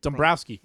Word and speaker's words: Dombrowski: Dombrowski.